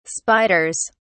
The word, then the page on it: spiders: British English